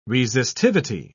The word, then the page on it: resistivity: British English